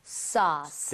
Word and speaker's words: sauce: AU나 AW 스펠링이 나오면 O보다 조금만 입모양을 벌려준다. 이 느낌. 그래서 소스보다는요, 소스. 소스 살짝만 벌리시면 되거든요. 한번 더. – sauce.